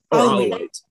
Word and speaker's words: Alawite: And Assad, like Hassan, is you'll have to pronounce it for me, Alawa? – Alawi. Alawite. Yeah.